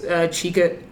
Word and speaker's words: Chika: There's Chika at Chika Stacy Poet C-H-I-K-A.